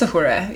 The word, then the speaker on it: Uhura: It's Uhura, yeah. But it's hard. I can see how you would, like, you might want to be like, Uhura. – I think it's like Uhura.